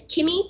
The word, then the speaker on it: Kimmy: But my name is Kimmy Kim, and it's with two M's. – Yeah, and it's spelled K-I-M-M-Y. – Yes. – There's some people that have been spelling it I-E, and that's not right.